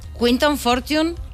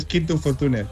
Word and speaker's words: Quinton Fortune: Quinton Fortune, ¿lo hemos pronunciado bien? – Sí. Bueno, aunque normalmente en España es Quinto Fortune, pero es igual.